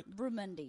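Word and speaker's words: barramundi: Did I say that right? – Bramundi, yes.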